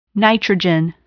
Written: nitrogen

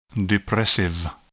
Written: depressive

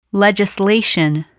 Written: legislation

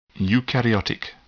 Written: eukaryotic

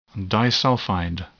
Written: disulfide

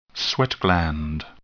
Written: sweat gland

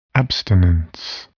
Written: abstinence

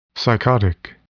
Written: psychotic

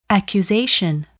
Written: accusation

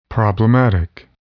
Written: problematic

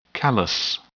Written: callus

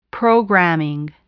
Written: programming

